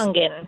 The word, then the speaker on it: Congen: How do you pronounce Congen. – It? Congen, just like Congen. It's spelled.